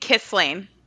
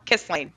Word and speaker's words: Kisling: You say it Kisling. Like Kisling. – Yep, just like kissing, but with an L in the middle. Kisling.